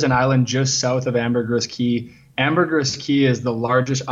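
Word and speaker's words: Ambergris Caye: Ambergris Cay. It's spelled like a key, like a key to open a door.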